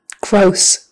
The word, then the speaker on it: gross: Gross, the word gross, it's spelled with a double S on the end, which would make you think that middle O is going to be a short O like ah, but it's not, it's a long O.